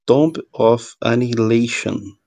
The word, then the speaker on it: Tomb of Annihilation: É Tomb of Annihilation não sei se é assim que fala, Bruno Lambert, me corrija. – Ah, tá, tá boa a pronúncia.